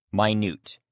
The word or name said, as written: minute